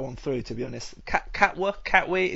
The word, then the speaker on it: Katwe: Just coming back to the film briefly, are you sure you've actually pronounced the title correctly? Because it sounds like Katwe. – That is the Ugandan pronunciation.